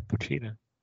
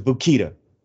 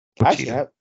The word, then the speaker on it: Pochita: It's Puchita, right? – Yeah, Puchita. – Okay, I was gonna say, don't tell me I'm butchering that name too. Or is it Bukita? – No. Puchita.